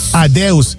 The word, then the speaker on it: adeus: Adeus.